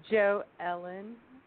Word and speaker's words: Jo Ellen: It's Jo Ellen. Like Joanne.